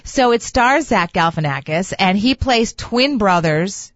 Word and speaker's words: Galifianakis: Yes, I've not seen it, but it's Zach Galifianakis starring in that, right? – You said that right.